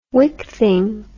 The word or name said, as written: wickthing